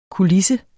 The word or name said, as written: kulisse